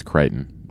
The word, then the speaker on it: Crichton: The same occurs when Colonel O'Neill and Stargate, with Colonel O'Neill and Stargate's brief candle and Crichton, is that how you say it, and Farscape's The Locket. I bet it's Crichton, probably named after Michael Crichton.